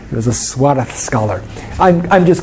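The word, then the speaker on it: Suárez: If you're precise about your Spanish, Suarez. Yes, it's this Castilian Spanish, so you have to say it like it's a TH.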